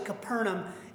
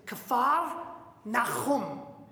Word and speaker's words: Kfar Nahum: Actually, Capernaum is the anglicized way of pronouncing the name of the town. I'm going to teach you a little Hebrew here. I'm going to make sure you get the pronunciation right. All right. So the actual name in Hebrew is Kfar Nahum.